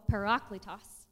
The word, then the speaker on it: parakletos: But the um, if you guys can put that on the screen, counselor there in Greek is called parakletos. Parakletos. And it is toss and not toast because I was like, pronouncing it all kind of wrong.